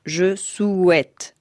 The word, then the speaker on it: je souhaite: Por ejemplo, la combinación de las letras O y U forma en francés el sonido U, no U. El Papa dice, je souhaite. – Je souhaite de tout cœur. – En realidad, lo que debería decir, bien pronunciado, es je souhaite. Repito, no es je souhaite. – Je souhaite de tout cœur. – Se debe decir je souhaite.